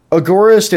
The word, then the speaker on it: agorist: It's really just – and we also said anarcho-capitalist and ANCAP, which agorist and voluntarist – agorist, is that how you say it? – Agorist is how I've heard it pronounced, yeah.